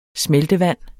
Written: smeltevand

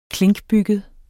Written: klinkbygget

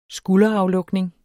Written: skulderaflukning

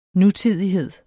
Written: nutidighed

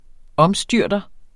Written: omstyrter

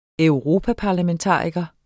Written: europaparlamentariker

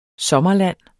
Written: sommerland

